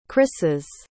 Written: chryseis

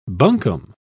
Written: bunkum

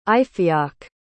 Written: Ifiok

